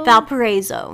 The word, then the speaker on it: Valparaiso: So I are hanging out at my amazing Airbnb in, how do you say this place's name? Valparaiso? – Valparaiso.